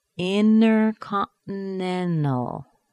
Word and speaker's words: intercontinental: And now we've got an N-T-A-L at the very end. That A-L is a weak syllable, so the T is gone. N plus T plus a weak syllable. Intercontinental. Intercontinental. Wow.